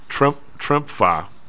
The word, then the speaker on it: Tremfya: Then there's Rexulti, whatever that is, R-E-X-U-L-T-I. It's 116 mil. Tremfya. What is that? Is that how you pronounce it? T-R-E-M-F-Y-A? – Tremfya.